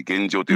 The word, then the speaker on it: 現場: うん、でまあそういう状況においてはですね大体あのまあ警察用語で言うと現状ってよく言うんですが現場と書いて現状って読むんですけどね、うんうん、その現場現状にはですね普通ですと警備本部というのが設置されるんですよ。